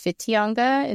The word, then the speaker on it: Whitianga: It actually started with the W-W-H-I-T-I-N-G-A, but it's pronounced Whitianga in the Māori language.